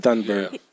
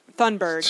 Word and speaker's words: Thunberg: Like the thing he said about Greta Thunberg or however you pronounce her name, Thunberg. – Thunberg.